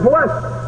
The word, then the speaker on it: blessed: Blessed be the God and Father of our Lord Jesus Christ, who hath blessed us with every spiritual blessing. See, they're the same letters in our English translation, B-L-E-S-S-E-D. But in the first case, they're pronounced blessed.